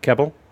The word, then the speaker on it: Kebbel: Kebbel? – Yeah.